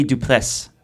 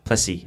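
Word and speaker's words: du Plessis: So the South African team, it was him, Brandon Grace, Louis Oosthuizen, and Henny Duplessis. Plessy, I think that's how you say it. Plessy, Duplessy, because it's like IS, but it's Frenchish.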